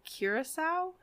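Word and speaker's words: Curaçao: But but so I did, today I did 2 ounces of tequila, 1 ounce blue Curacao.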